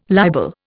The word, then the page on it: libel: Indian